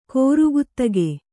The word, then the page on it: ಕೂರ್ಗತ್ತಿಗೆ: kūrgattige